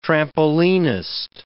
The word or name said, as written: trampolinist